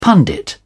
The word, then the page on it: pundit: British English